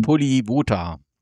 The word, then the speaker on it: Polivota: Da ist ja noch der A-Junior, der hat ja auch ein paar Spiele gemacht, hier Polita, oder wie ist das, wie wird das Spiel ausgesprochen? – Maximilian Polivota. – Genau. Polivota.